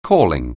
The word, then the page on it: calling: anglais